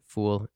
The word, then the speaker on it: foul: I mean, depending on how you pronounce it, but yeah, I pronounce it Fool, F-O-U-L.